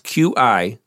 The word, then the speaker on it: Qi: So on this decade, we'll do Qi, and that's Q-I, and that's pronounced, I looked it up, Qi, like Qi, Qi.